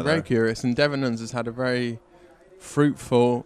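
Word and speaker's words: Devenyns: He was away towards the end with Dries Devenins. Is that how you pronounce his name? – Yep.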